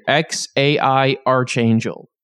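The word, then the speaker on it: X Æ A-Xii: There's an actual pronunciation here. X-A-I Archangel.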